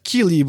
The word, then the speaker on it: Keighley: Tymczasem Jeff Keighley, bo tak się wymawia jego imię nazwisko,